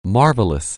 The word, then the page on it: marvelous: anglais